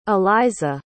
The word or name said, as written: elizah